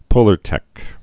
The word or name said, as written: Polartec